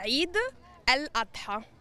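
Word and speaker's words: Eid al-Adha: Intanto, questa festa come si pronuncia? – È Eid el-Adha.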